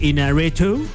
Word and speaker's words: Iñárritu: Con la nueva película de Alejandro G. Inarritu, ¿Ya? ¿Sí lo estoy pronunciando bien? – No, muy bien. – Alejandro excelente. – J. Iñárritu.